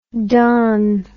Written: dahn

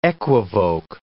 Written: equivoque

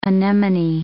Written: anemone

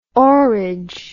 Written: oarage

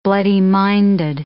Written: bloody-minded

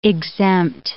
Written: exempt

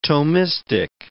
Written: Thomistic

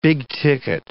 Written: big-ticket

